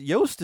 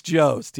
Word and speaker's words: Jost: Is it Yost? Is it Jost? – I believe it's Jost, yeah.